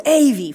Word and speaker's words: Avy: No, it's Avy. Finally, I remembered it. Rhymes with Navy.